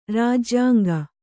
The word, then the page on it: ರಾಜ್ಯಂಗೈ: rājyangai